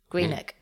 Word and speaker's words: Greenock: Greenock.